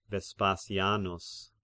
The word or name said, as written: Vespasianus